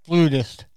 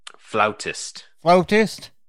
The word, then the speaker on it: flautist: That's flutist. – Flautist. Yeah, I have been told is the correct way to pronounce a person who plays the flute is a flautist.